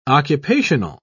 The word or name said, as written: occupational